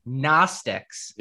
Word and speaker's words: Gnostics: No, I think the correct pronunciation is Gnostics.